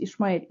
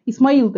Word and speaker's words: Ишмаэль: И причем именно вот в таком э, произношении, то есть Ишмаэль. Измаил, может, я привыкла просто к нему, то есть я слышала его неоднократно до этого, Исмаил точнее, то есть у нас распространено больше имя Исмаил.